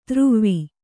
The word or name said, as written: ತ್ರುವ್ವಿ